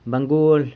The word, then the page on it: bangual: San Lucas Quiaviní